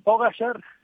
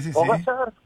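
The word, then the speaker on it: Pogačar: Pogacar, o Pogacar. – Es que tiene un apóstrofe ahí raro, que dicen que se pronuncia como si fuera una she, por eso.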